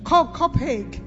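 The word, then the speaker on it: Copiague: in Copaig. Did I pronounce it properly? – Yeah, that's it. – Copaig.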